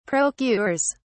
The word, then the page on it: procures: British English